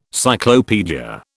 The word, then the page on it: cyclopaedia: British English